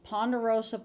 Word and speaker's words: ponderosa: ponderosa pine forest in the world.